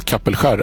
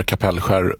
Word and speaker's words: Kapellskär: på E18 mellan Kapellskär eller Kapellskär, vilket uttal du föredrar.